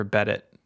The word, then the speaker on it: Bedit: It's spelled Bedit like Reddit with a B, which is fun.